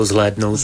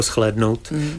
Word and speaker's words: zhlédnout: Což na Moravě obojí vyslovíme většinou jako zhlédnout. V Čechách jako schlédnout. Sch. Ať je to to nebo to.